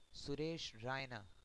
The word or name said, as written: Suresh Raina